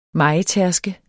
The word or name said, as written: mejetærske